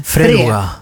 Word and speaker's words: Freluga: Jag var tvungen att googla hur man säger. Det står med betoning på första stavelsen. – Freluga. – Men det står också samma ordaccent som i tomten eller fluga. Och då blir det, men Freluga.